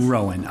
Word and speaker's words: Rowen: Rowan, R-O-W-E-N.